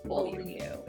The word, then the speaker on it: Beaulieu: I just give it an English pronunciation because it's easier. I just say Beaulieu. – Beaulieu. Is it French? – It is, yeah. So if I was French, I would say Beaulieu, but it sounds odd coming out of my mouth, so I just say Beaulieu.